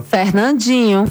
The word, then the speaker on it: Fernandinho: ¿Cómo se pronuncia Fernandinho? – Fernandinho.